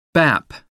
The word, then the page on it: bap: American English